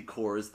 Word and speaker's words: cors: 30 cores of the finest flour and 60 cores, that's spelled C-O-R-S. – Okay, like the movie The Core.